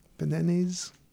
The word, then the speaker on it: Bananes: Bananes? Banane? – Banane. – Banane? Yeah, I know they don't typically pronounce S's.